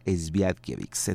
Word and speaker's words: Świątkiewicz: Eh, así se escribe Esguiewix y según lo que investigué se pronuncia Esviatkiewicz.